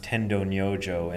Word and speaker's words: Tendo Nyojo: But Dogen kept on in China, and he finally ran across this teacher who's, in Japanese, his name is pronounced Tendo Nyojo, and he's pronounced differently in Chinese, but I never can get it right, so I'll just use Tendo Nyojo.